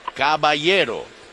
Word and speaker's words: Caballero: Okay, the correct pronunciation is Caballero.